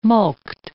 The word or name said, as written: mulct